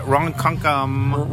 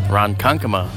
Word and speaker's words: Ronkonkoma: Ronkonkoma. – I think it's Ronkonkoma. Oh, you got it. What was it? – Ronkonkoma. – Say it again. – Ronkonkoma.